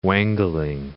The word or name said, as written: wangling